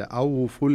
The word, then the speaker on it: أوفوا: لو يلحظ القارئ إلى المصحف يجد أن هناك ألف مستديرة وألف وألف بعدها، وهنا الألف الأولى التي في أوفو ما تنطق، خلاص أدغمت في اللي بعدها. أوفو الكيلة، ما تقول أوفو الكيلة، لا يصح أن تقول أوفو الكيلة، تقول أوفو الكيلة على طول، فهنا تدغم الألف الأولى في الألف الثانية وتصبح ألفا واحدة.